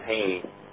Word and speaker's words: Haney: Call sign is Kilo Charlie 9, Golf Queen Radio, and last name pronunciation is Haney, H-A-N-E-Y.